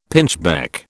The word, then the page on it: pinchbeck: British English